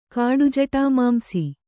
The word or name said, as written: ಕಾಡುಜಟಾಮಾಂಸಿ